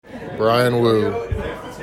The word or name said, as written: Bryan Woo